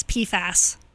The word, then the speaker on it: PFAS: So they have a link, a tab that says PFAS, P-F-A-S, PFAS contamination.